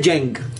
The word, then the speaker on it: Cheng: Pero se pronuncia Cheng. – Sí, Cheng. En realidad, Jeng. – Jeng. – Jeng. Sí, Jeng. Como la J de Jordan.